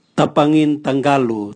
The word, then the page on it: tabwangin tangalu: Raga